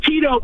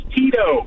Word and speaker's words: Tito: Hey, it's Tito, by the way. – You got my name wrong, man. That's Tito. – Oh, like vodka.